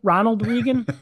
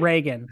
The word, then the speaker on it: Reagan: I think it's Ronald Regan. but his last name, they pronounce it Reagan.